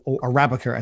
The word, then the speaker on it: Arabica: On percent Arabica or Arabica as it's actually pronounced, mm-hmm.